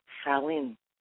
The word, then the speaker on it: Samhain: Before it was called Halloween, it was called Samhain.